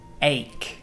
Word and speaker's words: ache: Por supuesto, significa dolor y no se dice H. No way, please don't say that. – Se dice ache. Ache. Like headache, stomachache, backache.